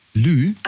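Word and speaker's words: lieu: Lü. Also Lü, das schreibt man also L-I-E und dann U. Lü.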